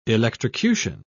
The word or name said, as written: electrocution